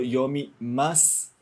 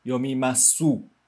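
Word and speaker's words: yomimasu (読みます): A gente escreve o final do yomimasu, né, com SU, mas a gente não vai pronunciar de novo yomimasu, né? A gente não pronuncia o U, a gente só pronuncia até o S.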